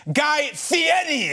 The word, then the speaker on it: Guy Fieri: Ladies and gentlemen, Guy Fieri.